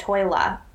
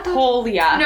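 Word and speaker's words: Tolya: and then they find out they see that Tolia, I always pronounced it in my head Toila. And now I'm annoyed. Tolia.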